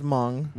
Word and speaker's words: Hmong: But it's pronounced Hmong. – Okay, yeah. There's a silent H there.